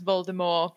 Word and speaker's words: Voldemort: I also like the J.K. Rowling says the T in Voldemort should be silent. It should be pronounced Voldemort.